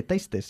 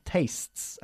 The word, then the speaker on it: tastes: Y no se dice tastes, sino tastes. Again.